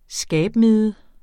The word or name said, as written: skabmide